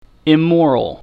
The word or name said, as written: immoral